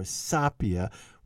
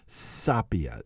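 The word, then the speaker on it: Sapìa: Right there, Avenia che Sapia or Sapia, we have to question how that word would be pronounced. Now, most people, as I told you, pronounce the word Sapia, but there is an accent over the I.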